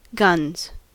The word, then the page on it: guns: American English